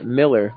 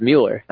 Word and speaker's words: Mueller: I was pronouncing it Miller last night on the broadcast. He pulled me aside and was like, gosh, I'm not trying to be like rude, but it's Mueller.